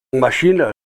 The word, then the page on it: mashina: Ninilchik Russian